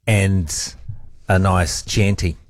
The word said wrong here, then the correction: Chianti: What's happened here is, I guess it's irony, but whilst I was addressing problems with my pronunciation of Michelin, I also added to it by mispronouncing Hannibal Lecter's words. Of course, it's a Chianti, isn't it? – Can we just confirm that?